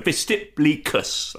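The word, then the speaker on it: vestiplicus: So much so that people that owned togas employed a special slave, or had a special slave called a vestiblicus, that's how you pronounce it, and it was their role to put your toga on.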